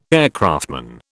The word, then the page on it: aircraftman: British English